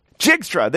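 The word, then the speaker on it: Dijkstra: I'm going to mangle. I've never pronounced Edward Jigriska. Okay, someone pronounce that for me. His last name, Edward Jigriska. What's his last name? Jigstra, there we go.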